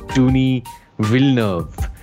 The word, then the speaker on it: Denis Villeneuve: It's Denis Villeneuve. – Not Denis? – No, it's he says in a lot of his interviews, it's Denis Villeneuve. It's because I think he's a French Canadian.